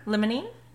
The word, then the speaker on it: limonene: Limonene.